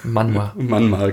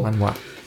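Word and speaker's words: Manhwa: Manma, genau. – Manma. – Ich dachte, irgendwas mit der ersten Silbe stimmt dabei nicht, aber es ist die letzte Silbe.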